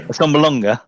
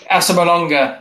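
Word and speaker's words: Assombalonga: Asambalonga. – Asambalonga, that's it.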